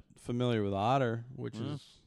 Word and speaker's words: OTA: And the Orthodontic Technicians Association, which is abbreviated as OTA, is apparently pronounced... – Otter. – Otter. That's how they pronounce it.